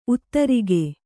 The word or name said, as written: ಉತ್ತರಿಗೆ